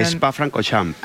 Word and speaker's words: Spa-Francorchamps: Spa-Francorchamps. – Ah, vale, lo he dicho bien. – Esto, todo esto con acento francés, imagínate.